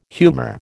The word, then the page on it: humor: American English